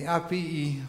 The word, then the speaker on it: Appii: But on the way, they stopped in the Appii Forum. Thank you, Patty. Good pronunciation. Appii with two I's, Forum, and the Three Inns.